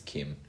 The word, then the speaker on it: Kim: Kim. It's not King, it's Kim. – Yeah, I said Kim. – I thought you said King. – The question is with the King. – Kim Jong-un.